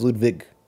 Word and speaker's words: Ludwig: I believe he says it's pronounced Ludwig. – They have a discussion. My memory is that they decided that it was pronounced Ludwig. – I remember Ludwig himself saying Ludwig.